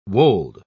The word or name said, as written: wold